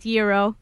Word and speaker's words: gyro: And is it pronounced gyro or gyro? – On behalf of all Greeks, it is in fact gyro.